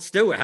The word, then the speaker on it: Stewart: Yeah, it's really, it's called Stewart. How hard is it to pronounce Stewart?